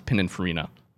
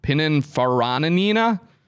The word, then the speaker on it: Pininfarina: And for the record, Chris, it took me a while to figure out how to pronounce Pininfarina up until like a few years ago. Pininfarinina? – Well, that's not right. No, okay. it's not.